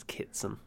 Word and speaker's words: Kitson: yeah um i think i pronounced it kidson it's not it's kitson my Kitson.